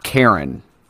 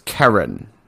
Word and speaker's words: Karen: She's like, it's not Karen. It's Karen. She said it's pronounced like the K-E-R.